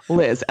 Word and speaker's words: Liz: That one, for me personally, always gets on my nerves because it's one syllable. Liz, L-I-Z, one syllable.